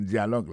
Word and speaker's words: dialogue: Tendez une partie in the dialogue, dans le dialogue là. Alors, mot dialogue, ça c'est dialogue. Ça nous a remarqué là, nous a remarqué que l'accent tonique là tombé sous le premier syllabe là.